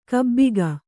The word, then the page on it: ಗಬ್ಬಿಗೆ: kannada